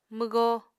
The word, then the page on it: mwgó: Apatani